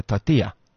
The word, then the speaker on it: tortilla: It's not, how can I please have a tortilla? It's a tortilla. – Tortilla. – Because the double L becomes a Y.